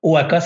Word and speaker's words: Hoaka: OAKA, ça s'écrit H-O-A-K-A.